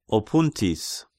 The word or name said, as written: Opountis